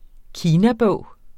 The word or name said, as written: kinabog